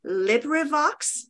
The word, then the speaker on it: LibriVox: LibriVox.